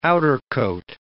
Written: outercoat

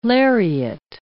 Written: lariat

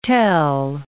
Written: tell

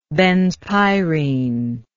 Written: benzpyrene

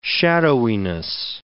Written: shadowiness

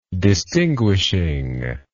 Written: distinguishing

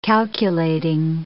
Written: calculating

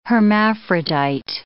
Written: hermaphrodite

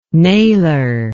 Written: nailer

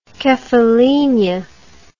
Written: Kephallinia

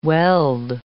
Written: weld

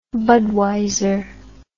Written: budweiser

